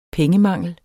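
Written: pengemangel

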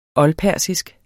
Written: oldpersisk

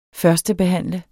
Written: førstebehandle

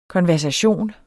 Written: konversation

